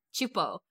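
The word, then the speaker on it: Csupo: Chupo.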